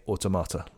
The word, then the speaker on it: Automata: But I had to fight to not say automata like everyone else says. But it is Automata, definitely.